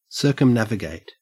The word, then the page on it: circumnavigate: Australian English